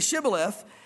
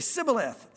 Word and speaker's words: Shibboleth: He said, say Shibboleth, and they would say Sibboleth. And so he'd be like, ah, you know, let me get a second try or something. I don't know. For he could not pronounce it right.